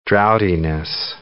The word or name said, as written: droughtiness